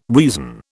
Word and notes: reason: British English

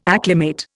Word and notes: acclimate: Indian

